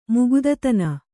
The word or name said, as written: ಮುಗುದತನ